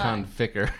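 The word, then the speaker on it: Conficker: Because it's con ficker. – God damn it. – Wait. That's he said it wrong. – He said it wrong. – I wrote it wrong. – Ficker. – What con ficker? – Like thick with an F.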